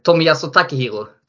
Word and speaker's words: Tomiyasu Takehiro: Och han har faktiskt förklarat uttalet och i Japan vänder man på det. Så Aha. han heter ju alltså Tomiyasu är ju förnamnet säger man först. Eller Tomiyasu. Eh, Tomiyasu Takehiro, typ med den betoningen säger man det.